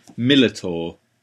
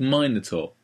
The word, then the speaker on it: Minotaur: Ian has a very funny way of pronouncing Minotaur. – Minotaur? – No. I wrote it down because it just caught me. Millitor. – Mill? – I thought it was Millitor. I'm like, you're a teacher. Pronounce it properly. You're an English teacher. It's Minotaur. Minotaur.